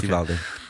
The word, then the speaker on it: Uvalde: I always say that wrong. Uvalde? – Yeah, that's how it's pronounced, Uvalde.